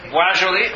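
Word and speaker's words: Boisjoly: When the shuttle Challenger blew up, the explosion lit a fuse in Roger, I'm not sure how to pronounce it, Beaujolais, is that... Beaujolais, okay. In his conscience.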